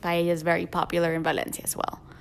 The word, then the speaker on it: Valencia: And oh, when you go there, make sure you pronounce it Valencia, not Valencia. – Yeah, that's the Spanish accent.